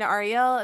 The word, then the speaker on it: Arielle: Hi, my name is Krystina Arielle.